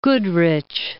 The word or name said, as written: Goodrich